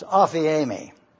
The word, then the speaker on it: aphiemi: It's pronounced aphiemi. It's spelled A-P-H-I-E-M-I. You may not be interested in writing this, and that's okay. The Greek word is A-P-H-I-E-M-I, aphiemi.